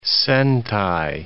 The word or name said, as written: centai